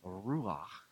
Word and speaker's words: Ruach: the breath of God, the Spirit, the Ruach. You kind of need a hairball in your throat to pronounce it right, as with half the Hebrew words.